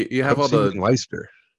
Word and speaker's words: Leicester: That's right, not London, Ontario, Canada, but London in the United Kingdom at the uh, Leicester Square Theater. L E I C E S T E R. I think you pronounce it Leicester.